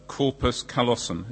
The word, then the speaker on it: corpus callosum: In Karen's case, her surgeon cut her corpus callosum. Is that the right way to pronounce it? Very good, thank you.